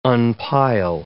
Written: unpile

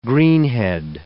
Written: greenhead

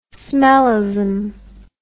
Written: smellism

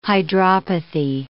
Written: hydropathy

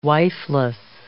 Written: wifeless

